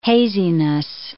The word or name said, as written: haziness